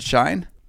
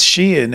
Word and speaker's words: Shein: And Sheehan, or Shine. Is it Shine? – It is Sheehan, and this is going to be the most important story of the week.